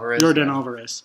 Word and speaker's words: Yordan Alvarez: I can't pronounce his name to save my life. – Yordan Alvarez.